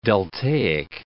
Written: deltaic

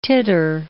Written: titter

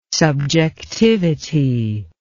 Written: subjectivity

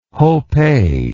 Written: hopeh

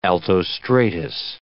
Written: altostratus

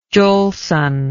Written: Jolson

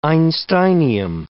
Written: einsteinium

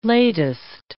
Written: latest

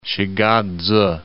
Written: Shigatse

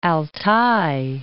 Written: Altai

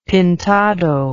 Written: pintado

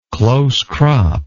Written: close-cropped

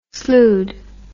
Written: slued

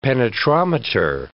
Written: penetrometer

